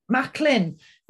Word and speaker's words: Macklin: Macklin.